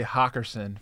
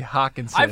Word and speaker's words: Hockenson: Don't feel bad, Drew, because he's been saying T.J. Hockerson for T.J. Hockenson.